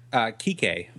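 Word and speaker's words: Quique: Kike Flores, I believe is how you pronounce it. It's Q-U-I-Q-U-E.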